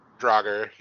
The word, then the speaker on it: Draugr: I feel like I'm pronouncing it wrong. Draugr? – Draugr, yeah.